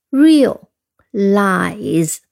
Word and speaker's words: realize: 嗯，它的读音 real lies 啊，两个音节。